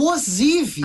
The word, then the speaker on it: Ozivy: Eu tava com dúvida também como pronunciava, então eu fui procurar. É o Ozivy.